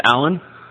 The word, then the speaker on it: Alan: First name's Alan, A-L-A-N, the correct way, the way Jesus pronounced it.